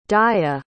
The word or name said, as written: daya